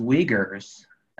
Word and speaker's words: Uyghurs: They're actually going on ethnic cleansing rampage right now with the ethnic group called Uyghurs. That's the actual pronunciation I've come to find out.